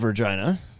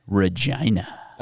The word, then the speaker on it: Regina: The city of Regina, for those who are not from here, that is how you say it. – Regina.